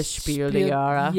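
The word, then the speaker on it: Spiel des Jahres: The Spiel der Jahre.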